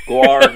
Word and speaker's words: guar: Not nar gum. Guar gum. – Like guar, like the band? – Yeah, but not spelled like that. No. – That's how I spelled it. – G-U-A-R, not G-W-A-R.